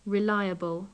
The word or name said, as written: reliable